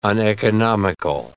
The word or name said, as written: uneconomical